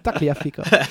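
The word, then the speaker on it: Tagliafico: Maar dat moet heel erg uitkijken met de uitspraak, hè, van Taliafico. – Taliafico.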